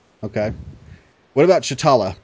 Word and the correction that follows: T'Challa: T'Challa or Chitala? – T'Challa. – T'Challa. – I was trying to impress y'all with my pronunciation of the name. – Your Wakandian. – I just screwed up.